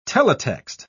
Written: teletext

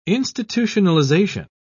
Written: institutionalization

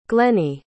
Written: glennie

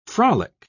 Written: frolic

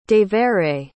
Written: devere

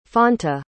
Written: fanta

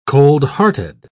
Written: coldhearted

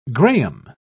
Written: graham

